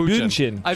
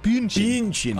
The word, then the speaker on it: Bündchen: Sources close to one Thomas Brady say that ex-wife Giselle Bündchen, I've never been able to pronounce Bündchen. – Her. – Bündchen. She's German, Nick. Bündchen.